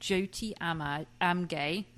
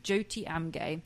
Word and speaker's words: Jyoti Amge: I've written down how to pronounce her name, because I always say Jyoti, but it's not Jyoti, it's Jyoti, okay. So it says 26-year-old Jyoti Amge, that's how you pronounce it, Jyoti Amge, is the smallest woman in the world at 24 inches tall and a mere 12 pounds.